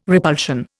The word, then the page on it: repulsion: Indian